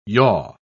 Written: yaw

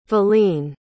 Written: valene

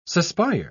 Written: suspire

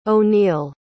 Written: oneill

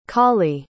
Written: cawley

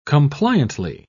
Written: compliantly

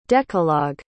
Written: decalogue

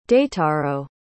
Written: daitaro

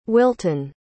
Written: Wilton

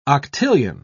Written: octillion

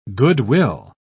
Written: goodwill

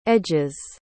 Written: edges

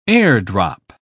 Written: airdrop

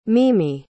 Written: mimi

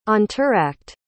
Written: entr'acte

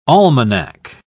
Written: almanac

